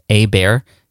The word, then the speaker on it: Hebert: but it's spelled H-E-B-E-R-T, but it's pronounced A-Bear.